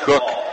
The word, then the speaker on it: Kuck: Bria Cook, last name spelled K-U-C-K, pronounced Cook, led Anna with 14.